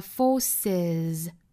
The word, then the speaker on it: forces: 这里呢其实我想把其中一个单词拿出来给大家单独讲一下，就是 force 这个词 F-O-R-C-E 这个单词变成了复数加了一个 s，但是它的发音居然要多加一个元音，读成了 forces 到底什么情况下需要加元音 a 呢？